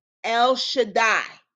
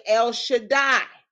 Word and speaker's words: El Shaddai: Hebrew, you can pronounce it El Shaddai. I've heard songs, they say El Shaddai.